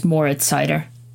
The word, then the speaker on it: Moritz Seider: The simple one is just Moritz Seider.